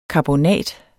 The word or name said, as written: karbonat